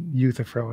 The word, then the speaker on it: Euthyphro: Euthyphro. Anyway. Euthyphro is how you pronounce that.